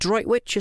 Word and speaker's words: Droitwich: Is it droitwitch? Is that how you pronounce that? That's amazing. – I believe so, yeah.